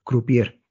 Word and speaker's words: croupier: Eva es croupier, pero creo que lo he dicho mal o no.